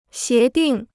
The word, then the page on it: 协定: xié dìng